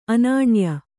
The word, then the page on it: ಅನನ್ಯ: ananya